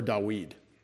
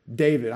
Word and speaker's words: David: I'd rather you call me David than David. or Dawid, even though that's how people will alternately pronounce my name in Hebrew. David, I'm an English speaker. It'd be better to call me that.